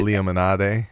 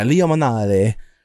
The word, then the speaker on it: Limonade: I don't remember how it's pronounced necessarily, but the Sprite Lemonade version is really good. Because it, Limonade. – Yeah, Limonade. it has that like soury tanginess to it that like normal Sprite doesn't have, but Lemonade does.